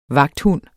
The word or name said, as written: vagthund